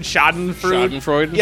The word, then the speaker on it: Schadenfreude: Like, was it Schadenfreude? – Schadenfreude. – Yeah, Schadenfreude.